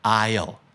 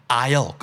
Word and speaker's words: aisle: aisle คำนี้ไม่ออกเสียงตัว S S เป็น silent S นะครับ aisle